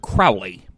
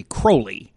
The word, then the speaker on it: Crowley: And we were doing one a while back about the history of Wicca, and we had to discuss someone whose name we pronounced Aleister Crowley, because, of course, we knew about him from that Ozzy Osbourne song. And we got a message saying apparently his name was actually Crowley.